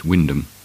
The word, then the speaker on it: Wymondham: Wymondham in Norfolk is pronounced Wyndham.